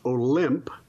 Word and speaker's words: Olympe: Okay, her first name was Olympe, O-L-Y-M-P-E, and her the rest of her name is de Gouges, D-E, and then final word, G-O-U-G-E-S, Olympe de Gouges.